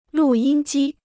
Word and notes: 录音机: lù yīn jī

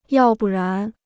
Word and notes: 要不然: yào bù rán

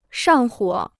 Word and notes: 上火: shàng huǒ

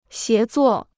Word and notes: 协作: xié zuò

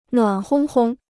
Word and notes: 暖烘烘: nuǎn hōng hōng

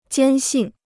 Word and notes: 坚信: jiān xìn

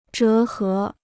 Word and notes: 折合: zhé hé

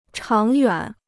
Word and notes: 长远: cháng yuǎn

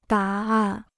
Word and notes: 答案: dá àn

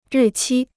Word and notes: 日期: rì qī

